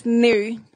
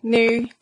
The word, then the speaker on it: Niu: And our surname is Nu, pronounced Nu as in new pair of shoes.